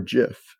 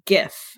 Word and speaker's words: GIF: So First one, is it pronounced GIF or JIF? – It is pronounced GIF, and that's because it's an acronym. And the first word in that acronym is graphics, which you say GIF.